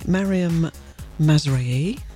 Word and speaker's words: Maryam Mazraei: We're joined in the studio by Maryam Mazraei, which I'm hoping I've pronounced right.